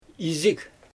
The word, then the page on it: iizɪg: Xyzyl